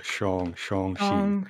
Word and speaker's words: Shang-Chi: Yeah, he does make sure to get the pronunciation right on the film as well. It's like Shang-Chi.